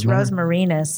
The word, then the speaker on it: rosmarinus: I think it's rosmarinus. – Rosmarinus. – Okay. Is it marinus or what? From Latin. Yeah.